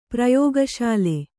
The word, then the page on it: ಪ್ರಯೋಗಶಾಲೆ: prayōga śale